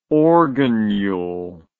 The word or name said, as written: organule